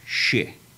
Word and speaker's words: shi: The number 4 is pronounced shi. – S-H-I. – Yeah, shi or she.